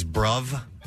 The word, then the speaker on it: bruv: How about, speaking of pronunciations, a popular one in slang is bruv. – Bruv. – With a V on the end.